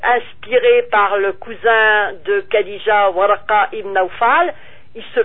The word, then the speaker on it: Khadija: Khadija. Le K-H, ça se prononce.